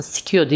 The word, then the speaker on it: SCIO: E, Shio şöyle aslında Sikio diye okunuyor ama biz Shio diyoruz. S-C-I-O diye yazılıyor bakarsınız.